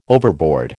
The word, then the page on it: overboard: American English